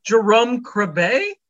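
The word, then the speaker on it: Jeroen Krabbé: So Deuce is interrogated by the police, played by none other than Jerome Crabb, if I'm saying that correctly. – I think it's Jerome Crabbe.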